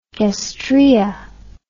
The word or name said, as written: gastraea